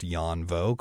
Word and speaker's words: Danh Vo: that's spelled D-A-N-H-V-O, but pronounced Jan Vo, called Take My Breath Away.